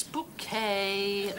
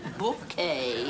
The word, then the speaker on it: Bucket: It's bouquet, B-U-C-K-E-T, bouquet. The accent on the second syllable.